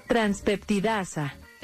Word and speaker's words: transpeptidasa: transpeptidasa transpeptidasa Hay como que, hay dos P ahí. transpeptidasa ¿Cómo? Transpeptidasa. Transpeptidasa. Te la, pronuncio bien para que sepas que hay una ahí en el medio.